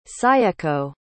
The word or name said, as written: sayeko